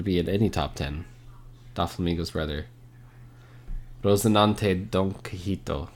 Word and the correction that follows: Quixote: Quixote. I said that wrong. Don Quixote.